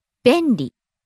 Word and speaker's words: 便利: 便利。便利。 – Starts high, comes down on the second syllable, ん, stays low. – 便利。